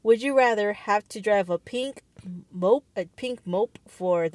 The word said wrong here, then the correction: moped: Mope or moped? – Oh shoot, it probably is moped. Why is it spelled like mope?